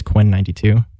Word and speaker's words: Quinn XCII: Some people might know him as Quinn XCII, but the actual pronunciation is Quinn 92.